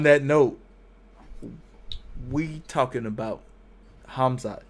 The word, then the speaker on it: Khamzat: And he's fighting this guy named Khamzat. The K is silent. The H is hard.